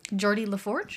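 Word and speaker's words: Geordi La Forge: Jordy LaForge.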